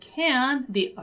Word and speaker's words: can't: And the word can, the affirmative version, is going to be complete, right? So can't, right? It ends abruptly, even though I don't pronounce the letter T, right?